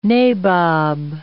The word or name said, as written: nabob